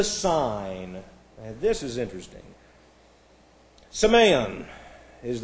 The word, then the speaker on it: semeion: It's S-E, and then we have M-E-I-O-N. This is S-E. You have an 8 in your class notes there. That's to force you to say that eta, long A. M-E-I-O-N. Semeion.